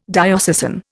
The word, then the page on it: diocesan: Indian